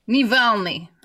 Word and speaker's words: Navalny: Did I say his name right? Is it Navalny? – Navalny. – I knew how to say it. Navalny, yeah.